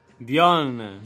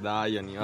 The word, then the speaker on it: Dionne: Dionne, detta male, Dionne, vabbè, ero convinto che la I non si pronunciasse A, quindi ho detto dai, mi lancio su.